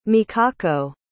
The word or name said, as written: mikako